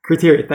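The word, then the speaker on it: Crateria: Am I pronouncing that right? Kratia? Kratia, thank you.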